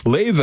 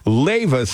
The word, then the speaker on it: Leva: Lave is spelled L-E-V-A. Lave Sleep.